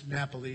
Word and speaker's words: Napoli: Now, in Italian, it's Napoli is how they pronounce it.